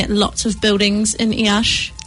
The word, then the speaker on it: Iași: That's how you say it, right? Iași. – Da.